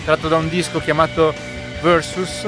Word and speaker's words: Versus: Non me ne voglia l'ascoltatore di prima che diceva che dice plus e non plus, parola anche inglese Versus, e quindi lo pronuncio come lo pronunciano i Pearl Jam stessi, anche se è una parola latina, anche questa che ha la provenienza è una parola latina, scherzando ovviamente.